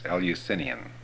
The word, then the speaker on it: Eleusinian: Eleusinian, that's the way you pronounce it.